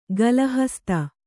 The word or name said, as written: ಗಳಹಸ್ತ